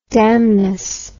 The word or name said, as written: damnously